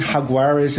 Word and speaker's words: Jaguares: Obviously, we are going to now talk about the Argentinian Jaguares, call them whatever you will, but it is Jaguares is the official pronunciation for the team.